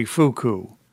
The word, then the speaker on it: Fuku: Boy, I hope I pronounced this correctly, Fuku. – Yes. F-U-K-U. Yep.